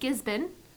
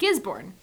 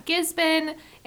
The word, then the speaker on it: Gisborne: We've got Gisborne in Australia. We've got Melbourne. We've got Brisbane. All of them are spelled with R's, but nobody pronounces the R's.